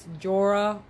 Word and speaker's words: Jorah: So, we see Jaron come back from that demolishment. Sorry, I was saying his name wrong. It's Jorah Mormont.